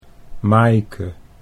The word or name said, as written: Maaike